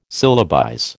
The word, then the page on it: syllabize: American English